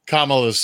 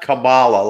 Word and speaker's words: Kamala: So it's not Kamala like the wrestler. Kamala.